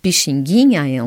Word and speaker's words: Pixinguinha: P-I-X-I-N-G-U-I-N-H-A. – Merci Marie. Je répète la prononciation correcte. Pixinguinha et non Pixinguinha.